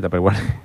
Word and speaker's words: Tupperware: En Tupperware, ¿no se dice Tupperware? – Tupperware.